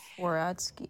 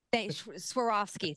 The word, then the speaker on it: Swarovski: Swarovski. I can't say either. You know, fancy crystals in them. Swarovski.